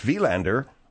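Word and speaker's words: Wilander: Mats Wielander, if you're going to pronounce it right, Margaret. – Well, are you doing the French? – No, that's nowhere near French. That's Mats Wielander. – Sounds almost like Dutch. – It's Swedish, or whatever the hell he was.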